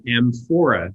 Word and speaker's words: amphora: Now, you also expanded my vocabulary, which is, I had never heard of amphora.